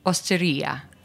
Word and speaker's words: Osteria: Osteria.